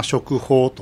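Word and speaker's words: 織豊: はい、皆さんよくご存知の織田信長と豊臣秀吉が政治の実権を握った時期のことでして、はい、織田のを、まあ、食と読みます、はい、それから豊臣の豊、うん、法と読みますけれども、漢字でそうですね。 – そうですね、うんうんうん、その 2 つをつなげて、まあ、食法というふうに呼んでいるわけです。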